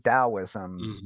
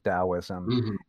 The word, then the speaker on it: Taoism: explain why we say Taoism with a D sound and why we spell it with a D, and maybe explain that difference between spelling Taoism with a D and with a T.